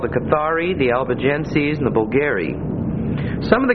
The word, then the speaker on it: Albigenses: The other two names are the Albigenses, Albigenses, and that's pronounced on the end like S-E-Z with a long E, sees, Albigenses. And the accent is on the middle syllable, Albigenses.